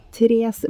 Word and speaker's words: Therese: Vi må jo ja. for guds skyld ikke uttale den e for dette er svensk. Ja. Tres blei aldri 16 år.